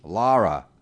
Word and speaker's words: Lara: So Todd Volkert and Laura Swanson coming up. – Lara. – L-A-R-A. – Is it a U?